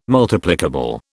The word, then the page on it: Multiplicable: British English